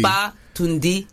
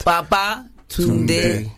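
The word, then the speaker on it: Babatunde: Tundi. Baba Tunde. – Tunde. – Tunde. – So say it like you're saying come back or returned. – Baba Tunde. – Yeah.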